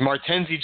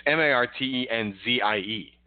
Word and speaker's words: Martenzie: I retract my original pronunciation of his name. I definitely just flipped the last few letters. I don't know why I said Martinez. It's Martenzie. So gotcha.